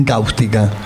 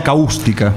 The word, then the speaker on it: cáustica: ¿Dónde va el acento en cáustica? ¿En la A o en la U? – En la A. – Ah, en la A. – No sería caústica.